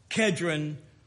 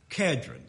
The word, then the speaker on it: Kedron: Why is it important for us to know that the Lord crossed the brook Kedron? I pronounce that with a hard K. It's spelled with a K in the Old Testament. It's spelled with a C. I still believe it's pronounced the same way.